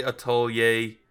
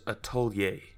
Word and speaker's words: Atelier: Atelier Ryza, and yes, I did say Atelier. It's spelled Atelier. – Okay. – The French word is Atelier.